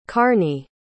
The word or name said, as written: karney